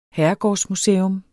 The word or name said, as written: herregårdsmuseum